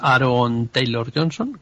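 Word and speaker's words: Aaron Taylor-Johnson: Vale, bueno, es Aaron Taylor Johnson, creo que vale.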